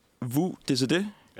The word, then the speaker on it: vous décidez: Og nu må du rette mig, hvis jeg siger det forkert. Mm. Men Vu DCD. – Ja. – Korrekt udtalt? Det er, det er rigtigt.